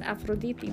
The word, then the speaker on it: Aphrodite: Aphrodite in Greek is Aphrodite.